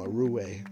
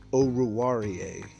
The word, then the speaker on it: Oruwariye: People have a large problem with it, so I'm gonna help you pronounce it, and then you can always remember it, because there's one podcast I listen to that they call him a Rue all the time, and his name is pronounced Amani Oruwariye, and he is the cornerback out of Penn State.